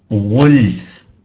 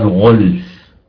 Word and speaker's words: غلف: ناخذ مثال اخر، وقالوا قلوبنا غلف، نذكر برضو كالعاده يعني تطريه الجو، غلف تلفظ هكذا غلف ولا يقال غلف، لان اللام مرققه والغين مفخمه،